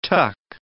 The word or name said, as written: tuck